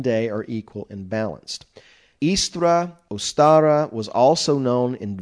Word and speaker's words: Eostre: From the goddess Istra, E-O-S-T-R-E, and there are varying spellings of that, but it's supposedly pronounced Istra.